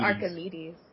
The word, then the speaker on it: Archimedes: Is it Archimedes or Archimedes? Is that a hard C or a soft C? I can't remember. – Archimedes. – Archimedes.